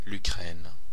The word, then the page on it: Ukraine: Belgique (Brabant); l’Ukraine; [lyˈkʁɛːn]; /y.kʁɛn/